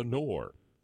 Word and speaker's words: Knorr: but had not been able to reach the NORR. That's spelled K-N-O-R-R.